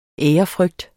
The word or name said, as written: ærefrygt